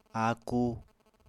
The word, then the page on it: aku: apatani